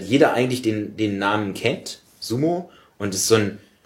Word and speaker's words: Sumo: Nun, ich bin derjenige, der Sumo gut findet, und das wäre jetzt sozusagen die exakte Aussprache, wäre langes O hinten, Sumo.